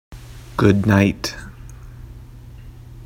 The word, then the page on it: good night: English